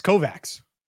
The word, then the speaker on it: Kovacs: According to Big Ten Network, it's Kovacs. So there's no CH in the pronunciation there.